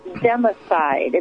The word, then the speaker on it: democide: It's called democide.